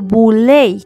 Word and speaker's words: Boule: De Boule es un acrónimo de Sigma Pi Phi y pronunciado boule Fue formada con un selecto grupo de hombres educados de raza negra y mujeres.